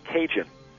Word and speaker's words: Cajun: So the next year, they fled to Louisiana, which was French territory, and these Acadians from Canada, the term ended up being pronounced Cajun.